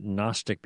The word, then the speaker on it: Gnostic: Now, you might have heard the word Gnostic before. It starts with a silent G, G-N-O-S-T-I-C, from the word for Gnosticism, an ancient heresy, and that's like knowledgeism is what it means.